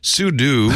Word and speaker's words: pseudo: By the way, ChatGPT says the pronunciation of pseudo can vary, but is commonly pronounced either sudo. Okay.